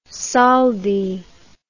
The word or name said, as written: soldi